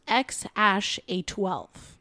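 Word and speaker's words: X Æ A-12: Yeah, look, according to Musk and Grimes, his name was XAEA-12, pronounced X-A-12 or XAI.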